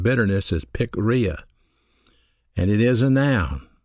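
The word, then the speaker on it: pikria (πικρία): The word bitterness is a Greek word pronounced pikria. It's spelled P-I-K-R-I-A.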